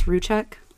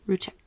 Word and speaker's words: Rzucek: Shannon Catherine Ruschek was born January 10th, 1984. Aww. Twinning. Strong-headed Capricorn. Her parents were Frank and Sandra. I think it's Ruschek. It's spelled R-Z-U-C-E-K. Ruschek. – Yeah, sounds right. Ruschek.